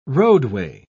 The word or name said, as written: roadway